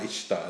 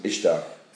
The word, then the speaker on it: Ishtar: I'm not sure where it's from, I can't remember, but it's pronounced Easter, but it begins with an I, Ishtar. I think it's Ishtar.